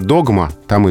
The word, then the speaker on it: догмат: Да, нас это сбивает, а, но в данном случае можно запомнить, где ударение догма, там и догмат.